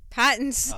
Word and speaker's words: Pattinson: Pattinson.